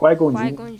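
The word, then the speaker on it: Qui-Gon Jinn: Jin, é isso mesmo? – Qui-Gon Jin. Qui-Gon Jin.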